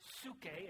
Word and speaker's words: psyche: The word soul here is the Greek word suke, is how you pronounce it, but it's spelled P S Y C H E.